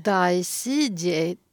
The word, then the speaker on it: Dai Sijie: Dai Sijie, tror jag. Det stavas D-A-I och efternamnet S-I-J-I-E.